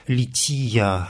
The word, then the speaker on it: лития: Мы обратимся сегодня к той части всенощного бдения, которая называется по-церковнославянски «лития». Это правильное церковнославянское ударение.